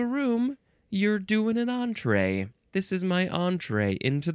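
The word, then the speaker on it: entrée: E-N-T-R-E-E. It looks like entree, but it is pronounced entree. Looks like you can you can emphasize either syllable. Entree, entree.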